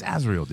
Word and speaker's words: Azrael: The sword of Arzael. – Yeah. – The classic one. – It's Azrael, dude. Who's Arzael? – Whatever, dude.